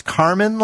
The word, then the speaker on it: Kármán: Blue Origin, Bezos' company, will go 328,000 feet, which is commonly known as is it pronounced Kármán line? – Yeah, yeah.